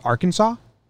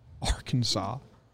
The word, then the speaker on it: Arkansas: You put A and R in front of Kansas, but it's pronounced Arkansas? – It's a tough language.